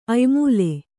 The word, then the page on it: ಅಯ್ಮೂಲೆ: aymūle